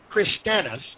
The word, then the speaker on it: Christianus: Now, the word Christian is of Latin origin and would have been pronounced as Christianus with a U-S, and after the Greek manner, it would have been pronounced as Christianos with a O-S.